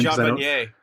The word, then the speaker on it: Vanier: Jean Vanier.